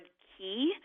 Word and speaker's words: QI: And I actually pronounce the word Q key, intentionally, in the sense that even if someone's not looking at the word written out and they hear me say key, K-E-Y, means that they're important.